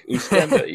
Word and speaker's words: Oostende: What does Ustend then? I can't. Remember, I don't know how to Oostende, pronounce it. – Yeah, Oostende, yeah.